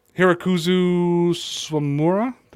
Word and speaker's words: Hirokazu Sawamura: Now, collectively, we may yawn at Garrett Richards and Marwin Gonzalez and Kiki Hernandez and Martin Perez and potentially Japanese import Hirokazu Sawamura. Did I say that right? – Yeah, or sounds good.